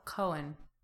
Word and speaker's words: Coen: C-O-H-N? – Coen. I think it's Coen. – Cohen? – But like, it's subtle. Coen. Like, almost like you're going to start yawning.